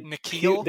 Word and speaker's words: N'Keal: Their first round picks of late, Sony Michel, Camille Henry, however you pronounce that guy's name. – N'Keal. They're N'Keal, their their fucking offense is terrible.